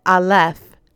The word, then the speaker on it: Aleph: If you said Aleph, then you are correct.